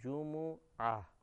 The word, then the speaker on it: جمعہ: میم کی تجدید کے ساتھ بغیر تجدید کے پڑھتے ہیں. لیکن عربی تلفظ کے اعتبار سے جیم اور میم دونوں پر پیش ہے جمعہ, جمعہ کا دن.